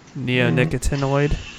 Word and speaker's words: neonicotinoid: Neonicotinoid? – Yeah. – Neonicotinoids.